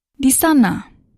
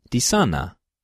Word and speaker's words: di sana: Di sana. Di sana. Di sana. – Di sana.